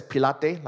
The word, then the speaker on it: Pilate: some of you pronounce it as Pilate, Pontius Pilate. I like to pronounce it as Pilate, like, you know, Pilates.